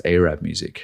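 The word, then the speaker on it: Araab Muzik: Maybe not in this form, but like there's an artist called Arab Music, and I'm not being ignorant. That's how it's pronounced, Arab Music,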